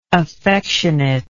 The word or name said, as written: affectionateness